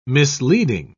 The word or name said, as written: misleading